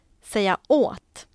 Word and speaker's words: säga åt: Men säga åt, det är ett partikelverb. Det betyder att man måste ha betoning på ordet åt. Så man säger säga åt.